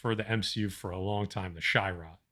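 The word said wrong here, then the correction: Shi'ar: Is that how you say it? Shi-Ar.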